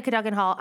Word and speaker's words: Cadogan Hall: I'm ready to play Cadogan Hall.